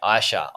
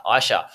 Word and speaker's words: Aisha: It's Aisha. – Aisha. – Aisha. Aisha. Yeah. – Apologies. Apologies. – The A is silent. It's confusing. – Okay. Aisha. Aisha.